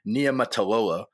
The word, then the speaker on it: Niumatalolo: So the rumors came out that they were going to hire the Navy coach, whose name I believe is pronounced Ken Niumatalolo, but